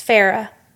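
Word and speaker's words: Feyre: It's Farrah. – Farrah. We have a very deliberate part at the beginning where one of her sisters says, Farrah, and she writes it out phonetically.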